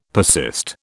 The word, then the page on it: persist: British English